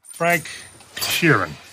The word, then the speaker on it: Frank Sheeran: Frank Sheeran.